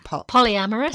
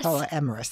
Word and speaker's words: polyamorous: It serves as a comical and slightly provocative number about a polyamorous, is that how you say it? – Polyamorous. – Polyamorous, that's right.